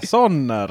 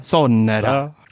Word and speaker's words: Sonera: Jag försökte uttala det finska här men eh, eller det är kanske mer Sonera. – Ja det är nog mer ja, Sonera. Sonera.